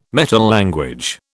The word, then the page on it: metalanguage: British English